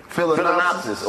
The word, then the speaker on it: Phalaenopsis: Phalaenopsis orchid.